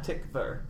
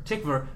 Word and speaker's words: Tykwer: Tickler. – Wickler, who twi- huh? Tykwer. Tykwer, who uh, directed Run Lola Run and obviously the Wachowskis.